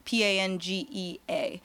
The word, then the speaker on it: Pangea: I might be saying it wrong. I don't know. P A N G E A. Sounds like you're saying it right.